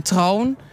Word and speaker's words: trouwen: Heel vaak moet ik het woord trouwen zeggen. Dat is dan troon. Je slikt die N in.